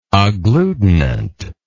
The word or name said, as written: agglutinant